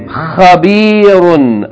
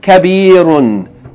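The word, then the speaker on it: Khabirun (خبير): Khabirun. Again. Khabirun. Be careful. You don't pronounce it kabirun with kaf, right? Kabirun. Kabirun means big.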